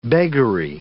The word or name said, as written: beggary